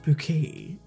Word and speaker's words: Bouquet: So when someone knocks on the door and they're like, Mrs. Bucket, she's like, no, it's pronounced Bouquet.